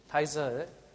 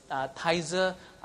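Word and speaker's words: Taizé: Some of the churches actually go into this thing called, have you heard of the, how do you pronounce T-A-I-Z-E? Tizer, Tizer. Is it Tizer? Is it called Tizer? Tizer. Is it Tizer, anyone? How do you pronounce that? Is it Tizer, okay.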